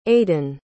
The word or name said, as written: aden